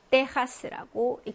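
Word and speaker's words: Texas: 미국의 텍사스. 자, 이거를 어떻게 읽냐면 스페인어로는 데하스라고 읽습니다. 자, 엑기스 발음 정리 한번 해봤고요.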